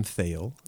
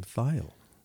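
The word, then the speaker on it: Thayil: It's not Kim Thiel, it's Kim Thiel. – Well, it looks like Thiel because it's T-H-A-Y-I-L, Thiel, but it's actually pronounced Thiel.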